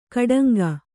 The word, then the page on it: ಗೂಢಾಂಗ: guḍhāŋga